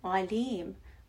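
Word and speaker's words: alim (عليم): Alim, always all-knowing. And make sure to pronounce this word with the ayn in the beginning, because the one with the alif that we covered already means painful, right?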